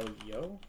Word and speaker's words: Yeoh: Yeah, I mean, obviously, like the most notable is what's her name, Michelle Yeoh. I think is how you say her last name.